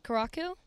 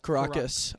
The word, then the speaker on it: Caracas: Wait, so how do you say it? Caracu? – Caracas.